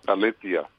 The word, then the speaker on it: Valencia: They pronounce it Valencia.